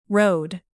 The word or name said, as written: rode